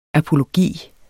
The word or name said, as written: apologi